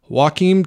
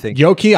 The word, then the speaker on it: Joachim: And then we're going to take a look at Sentimental Value, the new theme from Joachim Trier. I know that's not how you pronounce it. Joachim. I looked it up before the podcast and I forgot. – Joachim.